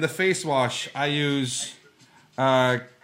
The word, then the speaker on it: Cremo: Cremo, because I'm sophisticated.